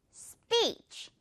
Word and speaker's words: speech: 역시 물총 발음 빵 안에 speech 다 들어오면 돼요. speech 아니고요. speech. 마지막에 이 부분은 살짝 속삭여만 줘야 돼요, 여러분.